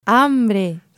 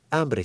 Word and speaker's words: hambre: All right, and now for today's pronunciation tip, let's look at the word hambre. – Hambre. – That's right, hambre, spelled H-A-M-B-R-E. Hambre. Guys, don't be that gringo that pronounces the H. In Spanish, the letter H is always silent.